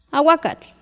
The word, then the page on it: awakatl: Náhuatl